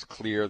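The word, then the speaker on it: Xlear: and that is Clear. Yes, that's Clear, that's spelled with an X, so it's X-L-E-A-R.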